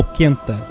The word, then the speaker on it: Quenta: É Quenta ou Quenta? – Quenta. Se pronuncia todas as letras.